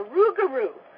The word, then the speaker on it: rougarou: and found there was one about a rougarou. This is a Michif pronunciation of the French phrase loup-garou, werewolf or goblin.